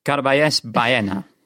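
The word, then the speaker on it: Carballés Baena: How close to an accurate pronunciation is that? – Yeah, pretty good. Carbajos Baena.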